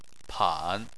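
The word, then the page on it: pan: tone 3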